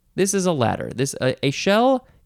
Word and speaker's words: echelle: ECHELLE. It is pronounced a shell.